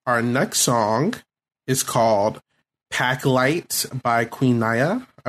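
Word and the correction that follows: Naija: I believe that's how you say her name. – Queen Naja. – Naja. Okay, okay, okay. Naja.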